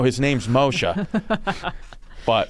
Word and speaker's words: Moshe: and we both love and respect Moshe Kasher. – Uh-huh. – Paul sends me a text once. He goes, you ever seen this kid Moses Kasher? It's Moshe.